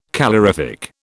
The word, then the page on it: calorific: British English